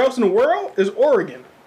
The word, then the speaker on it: Oregon: He said Oregon.